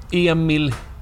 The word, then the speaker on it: Emil: So, Emil. – How do I spell that? – E-M-I-L.